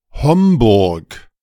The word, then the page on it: Homburg: German